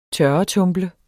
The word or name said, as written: tørretumble